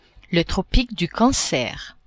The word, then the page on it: Tropique du Cancer: French